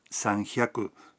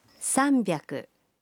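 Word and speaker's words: sanbyaku (さんびゃく): 300 no se pronuncia sanhyaku, sino sanbyaku.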